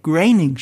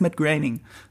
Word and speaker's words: Groening: Matt Groening hat immer gesagt, Graining spricht man es aus, habe ich gelernt. Man sagt Matt Groening. Okay. Es wird komplett anders geschrieben, aber es heißt Matt Groening.